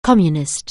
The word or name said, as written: Communist